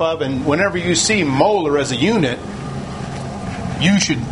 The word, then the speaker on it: molar: You pronounce it as molar when you see the capital M.